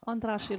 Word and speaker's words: Andrássy: You speak Hungarian, how do you say this? – You call it Terror Haza, Andrássy Road. – Okay, Andrássy Road. Andrássy? So, Andrássy Road, Terror Haza.